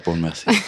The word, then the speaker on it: Bournemouth: You grew up in, I might mispronounce this, Bournemouth? – Yeah, Bournemouth.